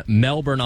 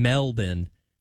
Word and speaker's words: Melbourne: Locals do not say Melbourne. So I think they do say Melbourne. – Okay. – So it's kind of like not pronouncing the T's in Atlanta. – Yeah. So the R is silent.